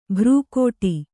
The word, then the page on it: ಭ್ರೂಕೋಟಿ: bhrūkōṭi